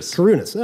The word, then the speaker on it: Kourounis: Kourounis.